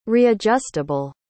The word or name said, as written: readjustable